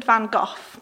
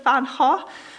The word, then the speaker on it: van Gogh: and he said that the V should be pronounced as an F, so it's Vincent and Van, and then the go would be with a silent G, so it's Ho.